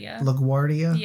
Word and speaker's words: LaGuardia: We took a Lyft from the airport to our hotel, which the airport, LaGuardia, I guess that's how you say it. – LaGuardia.